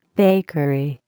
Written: bakery

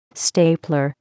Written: stapler

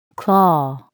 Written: claw

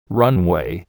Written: runway

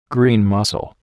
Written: green mussel

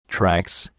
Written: tracks

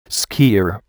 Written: skier

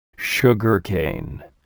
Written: sugarcane

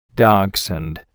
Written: dachshund

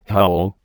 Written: hull

